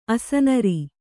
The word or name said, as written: ಅಸನರಿ